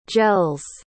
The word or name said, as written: gels